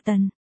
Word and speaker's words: Tần: Thái quốc ấn của trường công chúa Thái quốc. Thái quốc, còn đòi làm bổ khoái ư? Đỗ Văn Hạo không nhịn được nói. Tần, chữ đó đọc là Tần, ấn của trường công chúa Tần quốc.